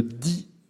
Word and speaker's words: Y: On va dans toutes les communes et on salue les Ipsoliniennes et Ipsoliniens qui habitent la commune picarde d'I. La commune, encore une fois, porte le nom I et ça s'écrit Y.